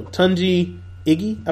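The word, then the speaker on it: Tunji Ige: So Tunji Iggy, I believe. It's spelled TUNJIIGE or space IGE.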